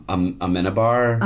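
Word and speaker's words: Amenábar: Amenabar? – Uh-huh.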